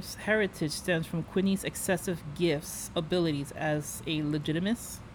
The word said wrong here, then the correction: Legilimens: I'm going to say it wrong. I know I'm saying it wrong. – Legilimens. – Thank you.